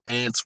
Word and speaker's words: Ants: So Ants rhymes with rants, and that's why I chose that, right?